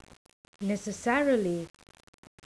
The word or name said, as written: necessarily